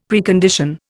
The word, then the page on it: Precondition: Indian